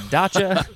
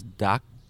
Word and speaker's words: dacha: So I think of this as pronounced as daca, as a daca. But the pronunciation is dacha? – Yeah, it shows the ch. I would think the same thing, daca, but you know, maybe both are fine.